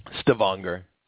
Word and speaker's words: Stavanger: Stavanger.